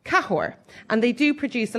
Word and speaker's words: Cahors: But again, totally opposite to what you, when you're going to order like an Argentinian Malbec, Cahors has a silent S at the end of it.